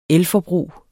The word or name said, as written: elforbrug